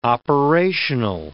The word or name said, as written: operational